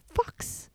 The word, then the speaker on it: FAVVKEX: That first band there we played for you is Fox, but it's spelled F-A-V-V-K-E-X.